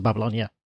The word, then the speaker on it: Babylon: I will take, yes, it's Hanging Gardens of Babylon, yeah.